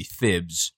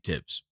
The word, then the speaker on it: Thibs: It was over a year ago, and they had like a 5-minute conversation over whether the fact that it's Thibodeau necessitates you changing the pronunciation of Tibbs to, I guess it would be Thibs, but no, it's indeed Tibbs.